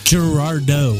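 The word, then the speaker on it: Girardot: Did I say that right, Giardo? – Gerardo. Gerardo. – yes, like Merlot or Cabernet.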